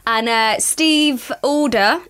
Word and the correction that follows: Adler: Is it Adler? – Alder? Okay. Steve Adler.